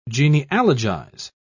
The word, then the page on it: genealogize: British English